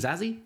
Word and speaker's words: Zazie: Zazie.